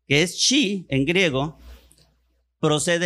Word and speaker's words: chi: Y en el griego, la X se pronuncia chi.